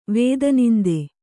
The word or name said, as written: ವೇದನಿಂದೆ